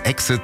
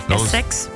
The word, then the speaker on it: Essex: Der hatte so eine Kultshow, The Only Way is Exit. – Essex.